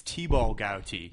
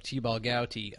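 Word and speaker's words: Thibault Gouti: He will be fighting Theobald Gauty. Did I pronounce that right? – I believe it's T-Ball Gauty. – There you go, T-Ball Gauty.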